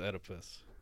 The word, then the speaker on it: Oedipus: His name is Oedipus.